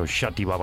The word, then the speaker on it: Xàtiva: Nits al Castell, y diréis, este, ¿por qué pronuncia también el catalán? El catalán o el valenciano, en este caso, Xàtiva, Valencia. Es que yo soy catalán, entonces pronuncio bien.